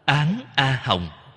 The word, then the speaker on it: Án A Hồng: Cách đọc của nó là Án A Hồng.